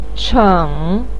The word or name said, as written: cheng3